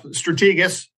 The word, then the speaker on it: Strategas: Joining us now is Jason Trennert, CEO of Strategus. I think I said that right. Did I? Jason, you can correct me. – Well, close enough. Strategus.